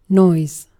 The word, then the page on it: noise: /nɔɪz/